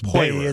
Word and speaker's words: Poirier: It was just Poirier. – It's Poirier.